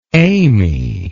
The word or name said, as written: aimee